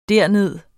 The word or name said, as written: derned